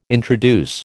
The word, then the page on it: introduce: American English